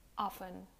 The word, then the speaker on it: often: The next often mispronounced word is often. You um, completely omit the T. You don't pronounce often, you say often.